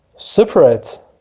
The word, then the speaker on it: separate: separate separate separate. separate تلفظ ادجکتیوشه، تلفظ فعلیش متفاوت میشه.